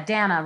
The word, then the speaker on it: Dana: Yeah, Dana rhymes with banana, works great.